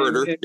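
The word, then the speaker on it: Huerter: Like maybe it's someone like a, uh, a Kevin Hoyter or Hooter, however you say his name. Hooter, yeah.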